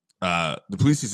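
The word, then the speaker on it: Du Plessis: Duplessis, I believe it's pronounced Duplessis. – Yes, I actually know that because I made it a point to learn it.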